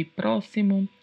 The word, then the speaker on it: próximo: Próximo é um adjetivo masculino que significa perto, seguinte, próximo. E tem essa pronúncia aí diferente no X, né? Próximo.